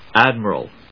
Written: ADMIRAL

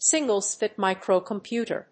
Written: single-chip microcomputer

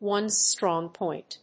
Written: one's strong point

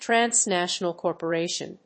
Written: transnational corporation

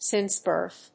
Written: since birth

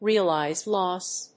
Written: realized loss

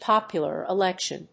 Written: popular election

